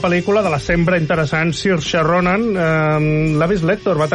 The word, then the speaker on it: Saoirse: que es diu Saoirse, m'agrada pronunciar-ho bé, ja que ho vaig aprendre, sempre hi ha de Saoirse o...